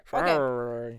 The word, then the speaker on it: February: I'm really proud that we both pronounced the R. – February.